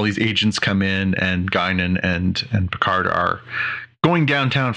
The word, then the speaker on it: Guinan: the woman who plays Guinan. – Guinan, yeah.